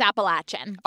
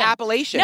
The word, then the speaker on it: Appalachian: And one of the things is how to pronounce Appalachian. It's Appalachian. – Other people said Appalachian. – No, it's because, do you know how you remember? It's, I'll throw in Appalachia.